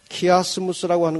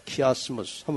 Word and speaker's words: 키아스무스: 우리가 여기서 계시록 공부하기 전에 잠깐 익혀놓고 지나갈 기법은 키아스무스라고 하는 것입니다. 키아스무스. 한번 따라하세요. 키아스무스.